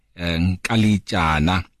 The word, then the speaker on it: Nkalitshana: This evening, we're joined by the newly elected uh, mayor, that is Councillor Connie Nkalitshana.